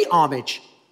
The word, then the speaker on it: homage: The H is silent in homage, Matt. Because the king had commanded this to be done for him, but Mordecai would not bow down or pay homage.